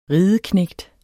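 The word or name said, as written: RIDEKNÆGT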